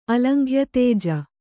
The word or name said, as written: ಅಲಂಘ್ಯತೇಜ